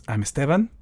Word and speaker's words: Esteban: It's probably simpler, and it always kind of annoys me a little bit when people call me Esteban because there's no emphasis on the last second in my, in Spanish.